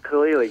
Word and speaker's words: Khalili: Yes, that's correct, Dr. Khalili, you said it perfectly.